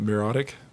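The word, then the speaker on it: Mirotić: How did I pronounce it? Mirotic? – Yeah.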